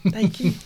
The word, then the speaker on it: Daikin: Daikin, je crois. – Daikin. Voilà.